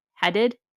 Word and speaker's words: Hadid: H-A-D-I-D? Hadid?